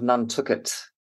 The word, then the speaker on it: Nantucket: There's a book called Lost in the Sea of Time, where the island of Nantucket, that's how you pronounce it, through some narrative conceit, gets thrown back into the sort of like Bronze Age.